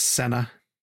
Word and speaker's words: Cena: Pronounced Senna. – Senna, like the driver.